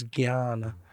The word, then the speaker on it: Jnana: Sometimes it's spelled Jnana, but it's actually, the pronunciation is Gyan.